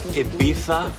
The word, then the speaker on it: Ibiza: It's pronounced Ibiza.